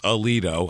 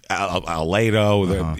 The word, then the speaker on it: Aledo: I looked it up. I'm sure there's some alternate pronunciation, but that's the way the rest of the world says it. Yeah, it's Aledo, even though there's an E.